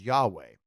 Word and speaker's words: Yahweh: In fact, to assure that they didn't make the mistake, the Israelites wouldn't even say God's name, the four Hebrew characters that basically translate to YHWH and are often pronounced as Yahweh.